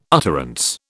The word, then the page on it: utterance: British English